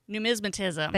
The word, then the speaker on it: numismatism: And one of the things too is that I mispronounce this, but numismaticism. – Numismatism. – Thank you. Numismatism.